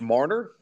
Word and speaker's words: Marner: Mitch, I think it's pronounced Marner.